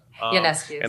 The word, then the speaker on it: Ionescu: Ionescu.